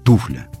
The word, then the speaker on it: туфля: Ну да, хотя по нормам литературного произношения, конечно же, правильно ставить ударение на первый слог, на букву «У». Тут даже вариантов никаких не может быть. Туфля и точка.